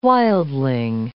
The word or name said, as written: wildling